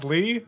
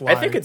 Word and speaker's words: Lie: It's pronounced Lee. See, that's how disconnected I am from Matt these days. I didn't even know it was pronounced Lee. I thought it was pronounced Lie.